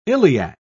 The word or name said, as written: iliac